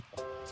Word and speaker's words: Koo: A rede social se escreve sim K-O-O. Só que a pronúncia oficial é essa mesmo, cu.